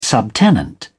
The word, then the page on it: subtenant: British English